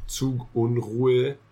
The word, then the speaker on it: Zugunruhe: And the U's are much more rounded, and the E sound is a much lower E sound than the English E. – Say it again. – Zugunruhe.